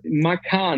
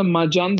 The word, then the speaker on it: Macan: Makan yani Makan'da mesela Porsche'nin ufak e, cipi orada da Macan demiyorlar mesela. Hmm makan diyorlar.